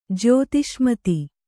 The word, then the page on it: ಜ್ಯೋತಿಷ್ಮತಿ: jyōtiṣmati